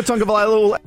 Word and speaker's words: Tagovailoa: Tua Tagovailoa.